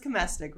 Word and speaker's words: Komestic: It's pronounced Komestic, rhymes with domestic.